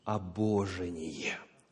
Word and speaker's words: обожение: Обожение. Ударение на второй слог.